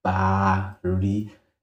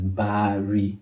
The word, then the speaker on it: Baari: and the proper pronunciation for my name is Baari, exactly pronounced how it is written. It is Baari.